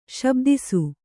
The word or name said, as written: ಶಬ್ದಿಸು